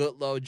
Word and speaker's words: Goodloe: So J-A-R-E-D. Mm-hmm. Goodloe, G-O-O-D-L-O-E. Uh-huh. Instagram, J-A Goodloe, G-O-O-D-L-O-E.